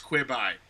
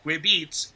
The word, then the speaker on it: Quibi: First of all, Quibi should be pronounced Quibi because it's quick bites. It's not Quibeats, Quibeats, quick beats.